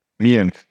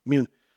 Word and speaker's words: Mune: I got to talk to you about, is it pronounced Mune or Moon? How do you pronounce that? – Mune, yeah. – Mune.